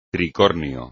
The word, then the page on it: tricornio: espagnol